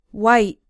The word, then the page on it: white: American English